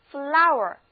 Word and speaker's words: flour: 那当 flower F L O U R 是一个单词的时候，它的意思是面粉、粉状物质。字母组合 O-U-R 读作 our，我们可以这样来记，开花后所结的果实磨成粉就成了粉状物质，那面粉呢显然也是粉状物质，所以 flour 就干脆用来指面粉和粉状物质了。